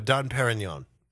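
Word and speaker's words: Dom Pérignon: Is that how you say it? – Dom Perignon. – Oh, right.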